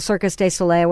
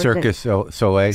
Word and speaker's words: Cirque du Soleil: It's Circus, how do you pronounce it? Cirque du Soleil, whatever. – Circus du Soleil.